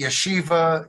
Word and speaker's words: Yeshiva: But there's a university, Yeshiva University, I get that right? – That's how I pronounce it.